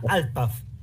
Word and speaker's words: Alpaf: Pero saluditos a Silvia, a Walter y a, y Alpaf. ¿Lo dije bien? La radio, ajá, correcto. La radio se llama Alpaf. Correcto, así es.